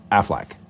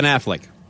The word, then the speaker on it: Affleck: Is a fleck, a flack, a fluke? Wait, how do you say it? I say Affleck. – Ben Affleck.